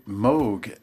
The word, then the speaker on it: Moog: Ensemble, vous avez sorti un ouvrage sur Robert, alors attention pour la prononciation, Moog ou Moog. – Alors lui, il disait Moog like Vogue.